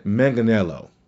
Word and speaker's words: Manganiello: I just don't think Cyborg is one of those type of people to survive, especially with Joe Magliano, or Magliano? Sofia Vergara's husband. His Deathstroke. I can't pronounce his last name. And Manganiello. There we go.